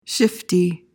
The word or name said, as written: shifty